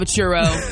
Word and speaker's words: churro: Have a churro.